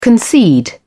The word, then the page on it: concede: British English